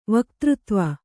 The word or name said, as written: ವಕ್ತøತ್ವ